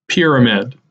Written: Pyramid

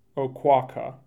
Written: Oquawka

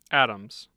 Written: adams

